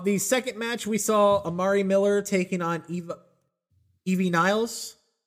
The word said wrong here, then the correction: Ivy Nile: Are you talking about Ivy Nile? – Yeah.